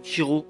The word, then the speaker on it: Ciro: on prononce Ciro en italien donc euh,